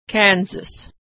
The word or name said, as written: Kansas